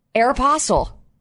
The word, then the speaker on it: Aeropostale: I would buy almost all my clothing from Aeropostale. – Stop saying it. – What am I saying incorrectly? – Aeropostale. You're putting all the emphasis in the wrong places. – How do you say it? – Aeropostale.